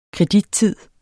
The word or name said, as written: kredittid